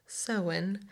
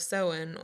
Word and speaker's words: Samhain: So the origins of Halloween come from the ancient Celtic festival of Samhain, mm-hmm.